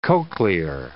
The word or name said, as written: cochlear